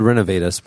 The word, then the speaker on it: Renovatus: It's spelled Renovatus, but it's like Renovatus.